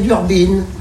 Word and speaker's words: Durbin: Non, on dit pas Diana Durbin, on dit Diana Durbin. Mais il y a écrit Durbin. On doit prononcer Durbin.